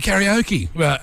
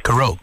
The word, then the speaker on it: karaoke: Yeah, they were talking about going out that night, and Vinny goes, we're going to karaoke. And Doogie proceeded to go, actually, Vinny, the correct pronunciation is karaoke, which comes from Japanese to mean without sound.